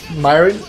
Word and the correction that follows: mirin: It's pronounced Mirin.